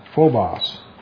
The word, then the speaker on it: phobos: P-H-O-B-O-S. Phobos. This is where we get our word phobia. Just from that. Phobos, actually, is how you pronounce it.